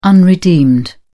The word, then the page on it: unredeemed: British English